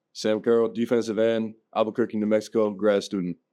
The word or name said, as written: Sam Carrell